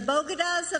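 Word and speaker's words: Bogotá: Apparently, she didn't like the way that you were pronouncing bodegas or Bogotas, the way you were pronouncing that.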